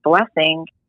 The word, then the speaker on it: BLSNG: She only has one song on her Spotify page, but her name is Blessing, and it's spelled B-L-S-N-G.